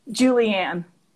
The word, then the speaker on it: Julianne: Is it is it Julianne or Julian? – Julianne.